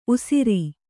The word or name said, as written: ಊಷರ